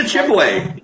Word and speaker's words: Chibwe: Like Bunda Chibwa. I can't pronounce it. Sorry. – That's all right. Bunda Chibwe. Yeah, Bunda, who has been called to join the first presidency.